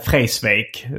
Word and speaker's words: Vreeswijk: Det är ju så här, om man säger Cornelis Vreeswijk så är det någon som rättade till Freisvik som han själv sa en gång.